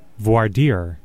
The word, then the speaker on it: Voir dire: Voir dire.